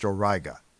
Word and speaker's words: Auriga: This is the alpha star in the constellation Auriga, or sometimes pronounced Auriga.